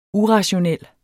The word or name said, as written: urationel